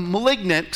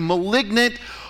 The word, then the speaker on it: malignant: In fact, a literal definition of envy is a, malag- a malignant, excuse me, malignant, let me say it correctly, malignant or hostile feeling.